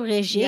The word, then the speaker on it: Régé: So it was like Régé. It's still Régé. Yeah. Still Reggie. – Exactly. Yeah. – Yeah. So it's like, how did you know it was a hard G? – I had to look it up and I had to look him up in the interviews and they would introduce him as Régé.